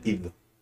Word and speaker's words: id: se pronuncia id, mm.